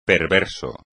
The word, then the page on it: perverso: espagnol